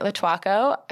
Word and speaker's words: Lotuaco: Today, we are welcoming Sydney Lotuaco.